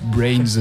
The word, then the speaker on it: BRNS: Exactement, et donc c'est finalement très prononçable parce que c'est juste Brains, on a enlevé les voyelles.